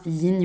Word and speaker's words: yin: Le yin yoga, est d'ailleurs, petite parenthèse, euh, on ne dit pas ying, mais bien yin, ça s'écrit Y-I-N, euh, c'est un des fondements euh, de la médecine traditionnelle chinoise, et je vous le confirme, il n'y a jamais eu de G,